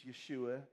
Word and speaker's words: Yeshua: And then when the Messiah, Jesus, Yeshua is the way we pronounce it, when he comes on the scene, all of a sudden this changes to this.